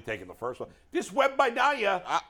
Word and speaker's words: Wembanyama: To say, how do you Wembanyama. – Wembanyama.